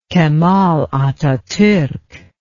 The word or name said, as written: Kemal Ataturk